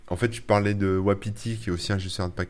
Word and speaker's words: WAPT: Il y en a un, on va voir si on en parle, mais qui s'appelle Wapiti. Alors ça s'écrit W-A-P-T, qui est en fait un, pareil, un gestionnaire de paquets qui est plus destiné au.